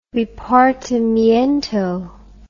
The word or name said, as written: repartimiento